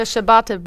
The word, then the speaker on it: ciabatta: Have you ever heard of ciabatta? Am I saying that right? This is the word, C-I-A-B-A-T-T-A.